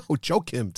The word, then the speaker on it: Joakim: No, Joakim.